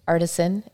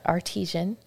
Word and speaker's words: artisan: And artisan, it's not artesian. You know, it smells the same-ish. It depends. It's the emphasis on the wrong syllable.